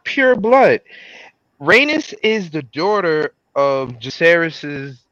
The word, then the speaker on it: Rhaenys: Oh, she's Rhaenys.